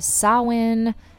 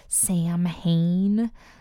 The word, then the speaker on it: Samhain: I hope you had a happy Halloween and a blessed Samhain. That is the preferred pronunciation for the holiday that you might sometimes hear as Samhain.